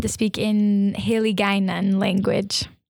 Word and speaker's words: Hiligaynon: Yeah, I pronounced it, I think, Hiligaynon, because it's spelled with gay in the middle.